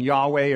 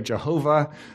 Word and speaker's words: Yahweh: It's pronounced often Yahweh or Jehovah.